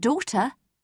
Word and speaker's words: daughter: In daughter, leave the GH out.